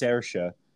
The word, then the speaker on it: Saoirse: First off, it's pronounced Saoirse.